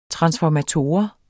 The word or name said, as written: transformator